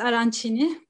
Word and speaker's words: arancini: Ben arancini yemeğini anlatacağım şimdi. – Arancin. – Evet, arancini diye okunuyormuş, yeni öğrendim.